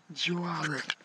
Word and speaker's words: Joivery: Joe Ivory. Joe Ivory. – Joe Ivory. J-O-I-V-E-R-Y.